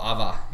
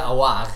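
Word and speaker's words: Aouar: Det ska vara Ava, eller Awa. inte Awar som alla sa, alla britter.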